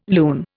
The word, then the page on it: lune: Indian